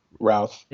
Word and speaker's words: Routh: How do you say his name? – Routh.